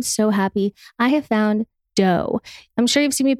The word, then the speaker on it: Deux: I first tried their chocolate chip cookie dough. And since I've tried all the other flavors and I'm obsessed with their birthday cake flavor, they're so delicious. So Dough, it's spelled D-E-U-X.